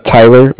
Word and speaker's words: Tyler: Tyler, my name is T-Y-L-E-R. – Uh-huh. – And I'm calling with Cardiff. It's spelled C-A-R-D-I-F-F, as in Frank Frank.